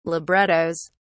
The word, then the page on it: librettos: British English